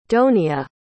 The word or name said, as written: donia